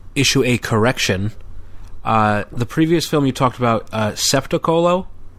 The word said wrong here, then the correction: Spettacolo: It's pronounced Spettacolo, and that's why I couldn't find it on IMDb. – Spettacolo.